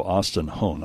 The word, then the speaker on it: Austin Hone: Now, you brought along some of the people that work in the Smart Lab, Connor Wilcox and also Austin Hone.